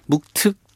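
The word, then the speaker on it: 묵특 (冒頓): 이럴 때는 묵특 또는 묵돌이라고 아. 읽습니다. 이게 흉노 발음에 가깝게 이제 맞추다 보니까 그렇습니다.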